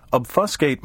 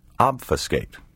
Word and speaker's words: obfuscate: Obfuscate may also be pronounced obfuscate. This alternative pronunciation, which has been heard in American speech since the early 20th century, was originally British.